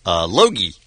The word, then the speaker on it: Luohe: The city is named L-U-O-H-E. How would you pronounce that? – Logie. – All right. So you'd add the silent G, I guess? – I'm just pronouncing the silent G, yes.